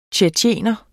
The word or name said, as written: tjetjener